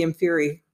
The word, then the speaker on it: Inferi: Rowling fell into the zombie thing by, in one of her later books, you know, Inferni, am I saying that right? – Oh, the Inferi.